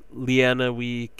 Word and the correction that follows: Lenawee: Lenawee County,